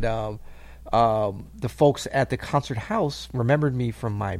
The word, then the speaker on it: Haus: Is it H-A-U-S House? It is indeed, yes, with the Viennese German.